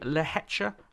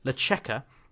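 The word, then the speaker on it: Lehečka: He also beat Jiří Lehecha, I can't say his name, Lecheka, who's the Czech player who's making some serious strides, and I think is another name you should watch out for.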